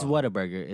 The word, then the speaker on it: Whataburger: It's Whataburger. It's Whataburger, Whataburger. – Whataburger. – It's spelled Whataburger. – Right. – You know, once you get used to saying it really fast, it's just Whataburger.